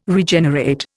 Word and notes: Regenerate: Indian

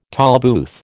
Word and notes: tolbooth: American English

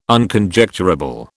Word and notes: Unconjecturable: British English

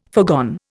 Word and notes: foregone: Indian

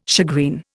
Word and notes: shagreen: Indian

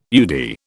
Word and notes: UDI: British English

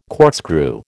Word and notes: corkscrew: American English

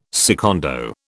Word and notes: secondo: British English

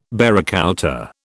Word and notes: barracouta: British English

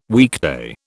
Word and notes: weekday: British English

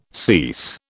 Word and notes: cease: British English

